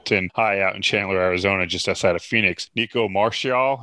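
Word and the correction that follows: Marchiol: am I saying that right? That's your guy. – It's Marquio. – Marquio. – Yeah.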